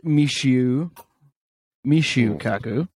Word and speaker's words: Michio Kaku: So I heard, I listened to a thing with apparently it's pronounced Michio Kaku, not Michio.